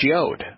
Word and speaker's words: yod: and so it was clearly a yad, and now it's pronounced yod.